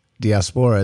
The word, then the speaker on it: diaspora: I think I've only seen that word in writing, and I had no idea that it was diaspora instead of dysphoria. Like, I really had the emphasis on the diaspora. – Wrong syllable.